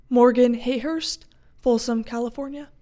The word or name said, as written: Morgan Hayhurst